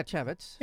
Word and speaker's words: Chaffetz: Chavitz, am I saying that right? Y- yeah, Chavitz.